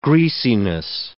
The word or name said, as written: greasiness